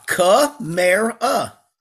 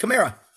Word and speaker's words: Kamara: Ah, Kamara. – So it is Mare. – Yep, Kamara.